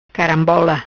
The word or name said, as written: Carambola